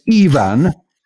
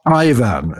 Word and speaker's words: Ivan: He says, for Ukrainian and Russian, E-I-V-A-N-S, the I in Ivan is pronounced as E, as in Iba Gumlad, which our non-British listeners is a joke about Yorkshire people. They're meant to always say Iba Gum. Anyway, so it's Ivan, not Ivan, as you said earlier, Saul, which is how the Welsh preparatively pronounce it.